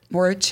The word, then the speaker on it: Mohorič: Yeah, like was it more Mohorič, how do you say the dropper post guy in the tour? – Mohorič, yeah.